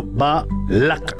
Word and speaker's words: Balak: Bien, vamos con el significado del nombre Balak. Balak se pronuncia en hebreo Balak, con el acento en la última sílaba.